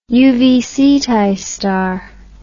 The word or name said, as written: uv ceti star